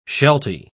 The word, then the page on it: shelty: British English